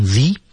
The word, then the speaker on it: the: 因为 administrative 前面的第一个因素是元音因素，前面冠词要读成 the 才对。